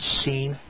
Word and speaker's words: Sin: I mean, the word is actually, the Hebrew word is pronounced seen, and it's spelled completely different.